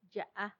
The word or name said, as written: yaa